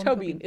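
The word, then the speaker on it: Tóibín: Tobin is how it's pronounced.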